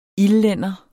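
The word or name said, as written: ILDLÆNDER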